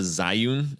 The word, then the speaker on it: Zhiyun: It's called the Zhiyun, I believe is how you say it. Z-H-I-Y-U-N.